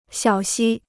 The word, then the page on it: 小溪: xiǎo xī